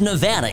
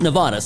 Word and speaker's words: Nevada: It's Nevada. It is not Nevada.